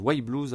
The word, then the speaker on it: Y Blues: Ça s'écrit Y Blues, hein, ça se prononce White Blues à l'anglaise.